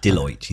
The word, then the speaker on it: Deloitte: Is it Deloitte? Am I saying it right? – Deloitte.